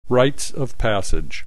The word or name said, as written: rites of passage